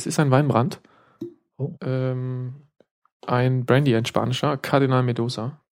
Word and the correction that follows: Mendoza: Medoza oder Mendoza? Mendoza.